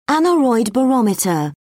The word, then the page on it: aneroid barometer: British English